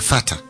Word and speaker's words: Vater: Por ejemplo, el alemán me dice, las R finales ya casi no se pronuncian. – Se las comen. – ¿No? Claro. ¿No? Como padre, que es parecido al inglés, es Vater. – Vater, sí, es verdad. – Y se escribe con R final, pero suena Vater. La R ya no.